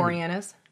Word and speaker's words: Boreanaz: Boreanaz.